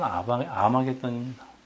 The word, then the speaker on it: 아마겟돈: 그런데 이게 이 지역이 무기또에서 있다고 해서 무기또 산이라고 불리우는데 여러분 히브리어로는 할 무기또, 무기또 산입니다. 헬라식으로 발음을 하면 이게 여러분 그 유명한 아마겟돈입니다. 여러분 이 다볼산에서 수많은 전투가 일어났고 그리고 우리의 인류의 마지막 전쟁이 일어난 데는 장소도 바로 이 아마겟돈입니다.